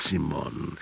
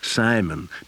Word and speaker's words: Simon: Nicht Simon, Simon. John Simon Guggenheim. Heute wird Simon Simon ausgesprochen, Kommandante.